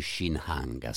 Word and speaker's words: Shinhanga: una mostra sull'arte degli Shinhanga.